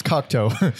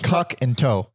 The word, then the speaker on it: Cocteau: It's Cocteau. Cocteau. Cock and toe.